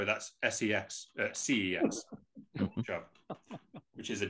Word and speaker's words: CeX: It's called CEX, Computer Entertainment Exchange, but they pronounce it as sex because they're trying to be a little bit edgy.